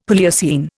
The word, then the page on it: Palaeocene: Indian